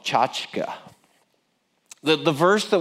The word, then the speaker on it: tchotchke: This is the word tchotchka. Now, you may hear it more often pronounced tchotchke. Officially, it's tchotchka. It's a Yiddish word.